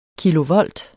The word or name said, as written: kilovolt